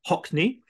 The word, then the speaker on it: Haughney: Her name's DEE Hockney. That is pronounced apparently like the artist David Hockney, but it's an Irish name, and we'll no doubt hear more about Dee's Irish heritage during the podcast.